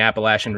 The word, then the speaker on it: Appalachia: It's going to be very encouraging, very promising, because, you know, a lot of the churches here in the Appalachian region, and for anybody listening, that's how you pronounce Appalachia. It's the Lasha. And someone's not from Appalachia when they call it Appalachia.